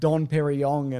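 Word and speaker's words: Dom Pérignon: I don't hate it because it's a staple of the franchise, but don't linger on a shot of like Don Perignon and whatever.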